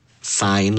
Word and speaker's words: sign: Se deletrea S-I-G-N. Pero se pronuncia sign. – Sign. – Sí, está un poco raro que la G sea muda en esa palabra.